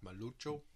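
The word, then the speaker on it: Maluco: After a little research break there, your nickname, Maluko. Have I got that right? – Malucho. – That's about as good as I pronounce it, yeah. – It's hard to do Portuguese with a country twang.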